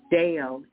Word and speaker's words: deo: that was K-L-E-I-S, and it's pronounced klais. And it is power and authority. To bind is deo, and it is D-E-O, deo, and it means to forbid, prohibit, declare as improper or unlawful.